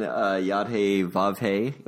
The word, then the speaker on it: Yod-Heh-Vav-Heh: And in a way, they're both attributive names, because even Yod-Heh-Vav-Heh, if I said that correctly. – Well done. Yes, the Tetragrammaton.